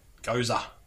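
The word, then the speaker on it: Gose: The style is Goza. – Oh, yeah, okay. Huh. – And for those that don't know what that is, that's G-O-S-E. We looked up the pronunciation. Goza.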